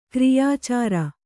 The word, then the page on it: ಕ್ರಿಯಾಚಾರ: Kannada